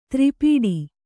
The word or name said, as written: ತ್ರಿಪೀಡಿ